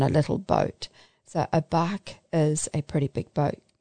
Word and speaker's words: barque: Some of today's symbols are that of the church as a boat, known as the bark of Peter. And if you don't know what a bark is, it's spelt B-A-R-Q-U-E.